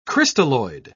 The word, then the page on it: crystalloid: British English